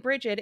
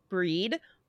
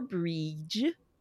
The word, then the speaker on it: Brigid: So, and I keep saying Brigid, it's pronounced Breed or Breege or Breege.